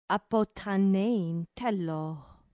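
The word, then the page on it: ἀποθανεῖν θέλω: ap ʌ t h an ɛ i ː n t h ɛ l ɔː; apothanein thélô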